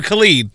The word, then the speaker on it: Khalid: Is it Khalid or Khalid? – Khalid. – Oh!